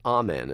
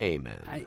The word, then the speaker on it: Amen: And you pronounce it Amen as opposed to Amen. – It's sort of like reflexive. I kind of prefer Amen, but what comes out is Amen for some reason.